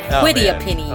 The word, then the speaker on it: quetiapine: Quatiapine? – Quetiapine? – Quetiapine? – Quetiapine. – Oh, no. Yeah, there you go. Quetiapine.